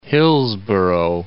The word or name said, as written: Hillsboro